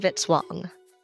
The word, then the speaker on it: Vitswong: Music by Kevin Vitswong, rhymes with hit song.